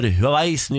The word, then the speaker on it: Huawei: Det var lanseringsfest for Huawei's nye telefon i går. P90 Pro tror jeg den heter. – Ja, vi har vel nå funnet at det uttales Huawei.